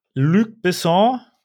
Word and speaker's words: Luc Besson: Luc Besson, habe ich das richtig ausgesprochen? – Ja.